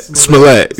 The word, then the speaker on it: Smollett: Smollett.